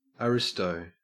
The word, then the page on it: aristo: Australian English